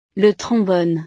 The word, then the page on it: le trombone: frances